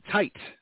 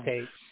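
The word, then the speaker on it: Taitt: Happy birthday as well to Alex Datcher, Ensign Tite. I believe it's Tate. You got to really be careful with pronunciation of this one here. It's T-A-I-T-T. – I think it is Tate. – Yeah, I'm going to go with Tate, okay?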